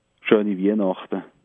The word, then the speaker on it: Schöne Weihnachten: Schöne Weihnachten. Schöne Weihnachten. Schöne Weihnachten. A beautiful Christmas night. Is that what that means? – Well, yes. Literally? More or less, yes. Yeah, great. Well, schöne Weihnachten to you and your family.